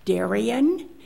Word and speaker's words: Darien: My name is Nancy Darien, and that is spelled D as in devil, A-R-I-E-N.